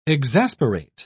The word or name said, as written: exasperate